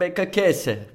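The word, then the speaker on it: Beccacece: ve sanki Sese diye okunuyor gibi ya, geliyor bana. Forvoda Beccasese. Öyleyse ee, doğrudur ama sanki Topçuluğu var mı?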